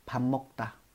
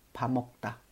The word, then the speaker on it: 밥 먹다: 首先， 밥 먹 다， 밥 먹 다，啊，分开来读是这样子的哈，但这个地方的话会有一个音变啊，밥 下面这个收音啊会变成 m 啊，读成 m，所以连起来就是 밥 먹 다， 밥 먹 다，啊，吃饭。